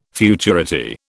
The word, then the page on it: futurity: British English